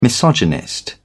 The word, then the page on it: misogynist: British English